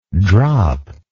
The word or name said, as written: droplike